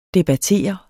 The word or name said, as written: debattere